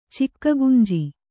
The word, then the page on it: ಚಿಕ್ಕಗುಂಜಿ: Kannada